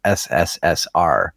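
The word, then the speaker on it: SSSR (СССР): CCCP, which is pronounced SSSR.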